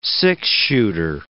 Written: six-shooter

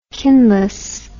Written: kinless

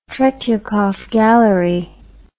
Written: Tretyakov Gallery

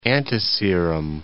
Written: antiserum

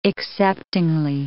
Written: acceptingly

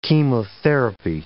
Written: chemotherapy

